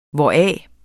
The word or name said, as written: hvoraf